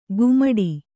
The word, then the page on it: ಕುಮಟಿ: kumaṭi